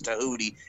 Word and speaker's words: Tahuti: He used to be Tahuti Miles, and that made me laugh because his name was Tahuti. It rhymes with Hootie.